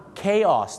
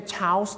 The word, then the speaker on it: chaos: เขาออกเสียงว่า chaos นั่นเองครับไม่ใช่ chaos นะฮะไม่ใช่ chaos นะฮะเขาออกเสียงว่าchaos